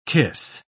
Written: kiss

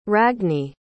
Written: ragni

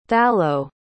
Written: thallo

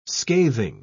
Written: scathing

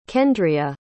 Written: kendria

